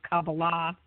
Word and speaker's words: Kabbalah: And when I found Kabbalah, and the correct pronunciation is Kabbalah, for